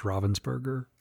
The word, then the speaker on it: Ravensburger: And I actually want to say it's pronounced Ravensburger. – Ravensburger? Yeah. Really? – German. – German. – German. – It's German.